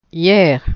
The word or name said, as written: hier